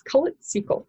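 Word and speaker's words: Kholat Syakhl: It's spelled Kholatsykhl. I looked up how to pronounce it, and I'm pretty sure I'm somewhat close. – We are professionals. We look up how trying to be. And it's colored sequel.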